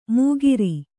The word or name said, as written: ಮೂಗಿರಿ